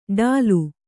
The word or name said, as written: ಢುಲಿ